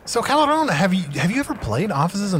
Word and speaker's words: Caball'on: Am I saying this right? Caball'on. – Caball'on Valentin. – Caball'on. – Yes.